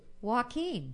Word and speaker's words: Joaquin: why you say it like that? – Philip Seymour Hoffman. I thought that's what it was. Jocelyn? – Did you? – What is it? – Joaquin.